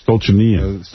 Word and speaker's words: Stolichnaya: Yeah, Stolichnaya. – Stolichnaya. – Stolichnaya, you say? – Stolichnaya. – Yeah, see, I don't even know that. – Chill. You're putting extra syllables in it.